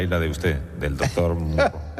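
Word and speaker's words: Moreau: Es el, a ver, que lo busco aquí, doctor Moro. – ¡Es Murro! – ¿Qué? – ¡Murro! ¡No, Moro!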